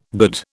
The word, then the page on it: good: British English